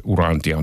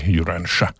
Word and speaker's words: Urantia: No se Urantia on tietysti suomalainen ääntämys, englanniksi se Urantia.